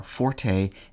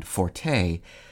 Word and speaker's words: forte: Common choices in American English are forte and forte, but many usage commentators recommend matching it to fort, since the E is not pronounced in French.